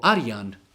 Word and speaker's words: Arjan: Ali ovako kako se izgovara na bosanskom jeziku Arjan, Arjan sa a, bez ajna, Arjan,